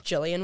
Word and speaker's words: Gillian: And I have to spell it out slowly, like letter by letter, because it's Gillian with a G and Villela.